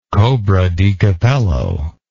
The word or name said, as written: cobradecapello